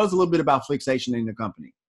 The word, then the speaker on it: Flixation: Did I pronounce that right, Flixation? – Flixation, that's the name of the company.